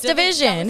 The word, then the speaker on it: DVSN: Like, it's Division, which I don't know if we even said that right now. – It's Division. Division. We're doing Division.